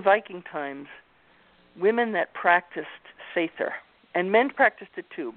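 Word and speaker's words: seiðr: I wanted to ask you about this word, S-E-I-O-R, and how do you pronounce that? – It's a funny-looking D. It's called Sather.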